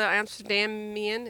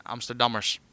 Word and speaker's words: Amsterdammers: We'll just keep supporting all the Amsterdamian. Is that how you say it? – Amsterdammers.